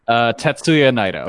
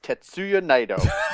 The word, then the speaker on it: Tetsuya Naito: Tetsuya Naito. – There you go. All right. – This is Tetsuya Naito.